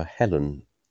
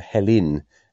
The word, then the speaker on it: Helen: One thing I wanted to say was we've got a daughter called Helen, and perhaps it's because we're down south, but we call her Helen as it's spelt. But in the Archers, it always grates on me when people, especially Pat and Tony, call her Helin, as if it's her name spelled H-E-L-I-N.